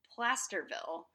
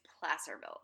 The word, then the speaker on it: Placerville: So there you have my hometown of Placerville, California, sometimes and most times horribly mispronounced as Plasterville. And, of course, in high school we had a... – It's good, Placerville. – It's Placerville.